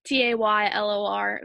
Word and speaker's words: Taylor: So my Instagram is Taylor Vahey, T A Y L O R V A H E Y.